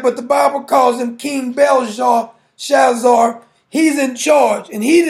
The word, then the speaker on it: Belshazzar: I think it's pronounced King Belshazzar.